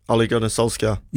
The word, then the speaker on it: Ole Gunnar Solskjaer: Ole Gunnar Solskjaer.